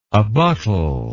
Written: abuttal

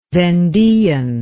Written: vendean

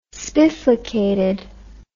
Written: spiflicated

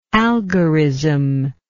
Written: algorismic

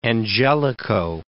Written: Angelico

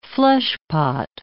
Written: fleshpot

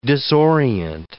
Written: disorient

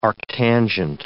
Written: arctangent

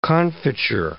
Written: confiture

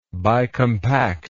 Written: bicompact

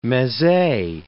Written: meze

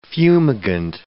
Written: fumigant

